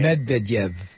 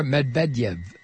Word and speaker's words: Medvedev: Medvedev. – Yes, thank you. – Or Medvedev.